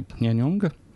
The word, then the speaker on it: Pyongyang: So, and all of this internet infrastructure is in the capital city of, I'm not going to pronounce it properly, Pyongyang, I think it is.